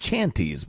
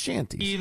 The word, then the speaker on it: shanties: It looks like chanties, but it's pronounced shanties.